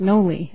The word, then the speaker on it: Noelle: Appearing on behalf of defendants, and it's pronounced Nolle, actually. – Nolle, okay. N-O-L-L-E, like the plea, Nolle? – Exactly. – Okay. – Only it's spelled Noelle.